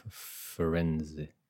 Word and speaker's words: Firenze: From Firenze. – Gen Z don't even try. – Where did you go? – Firenze. – So that's where Ezio Auditore was from. – That's where I first learned Firenze is Italian Florence.